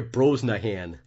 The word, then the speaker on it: Brosnahan: Rachel Bosney. I can't read. Brosnahan. That's not right. Am I saying that right here? Brosnahan.